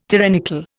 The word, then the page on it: tyrannical: Indian